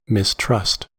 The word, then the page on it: mistrust: American English